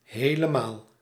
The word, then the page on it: helemaal: /ˌɦeːləˈmaːl/